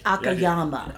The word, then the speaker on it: Akiyama: Just say Akiyama. – Yakiyami. – Akiyama. – Akiyama. Why are you telling me to say it when you just said it? – Because you mispronounced it, so I was correcting you.